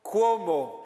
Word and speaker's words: Cuomo: Como dijo Cuomo, así se pronuncia, Cuomo de CNN, el reportero Cuomo.